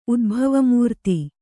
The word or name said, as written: ಉದ್ಭವಮೂರ್ತಿ